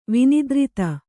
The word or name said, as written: ವಿನಿದ್ರಿತ